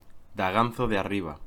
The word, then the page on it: Daganzo de Arriba: Spanish